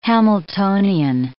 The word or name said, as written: Hamiltonian